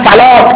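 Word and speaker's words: صلاة: مثلا صلات که با واو می نویسن این واو در نوشتار میاد اما در کتابت میاد اما در تلفظ نمیاد اما بعضی از چیزها هستن که لفظاً ثابتن خطاً و نوشتاراً به تعبیری ثابت نیستن مثل چی؟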